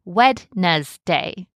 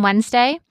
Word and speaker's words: Wednesday: Many people learn to say it in their head like Wednesday. And even though we don't pronounce the word that way in everyday speech, the spelling voice helps us remember the letters. And just a fun fact here, what's up with the D in Wednesday?